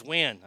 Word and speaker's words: Nguyen: Nguyen? Nguyen? Because we had a bunch of them in school, and we always tried to pronounce their name. They're like, it's Nguyen. I'm like, it can't be.